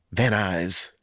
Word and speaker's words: Van Nuys: You don't pronounce both N's in Van Nuys, right? Like it's Van Nuys. – Van Nuys. – Not Van Nuys.